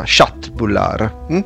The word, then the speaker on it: Köttbullar: Il piatto tradizionale svedese si scrive Köttbullar e si pronuncia Köttbullar.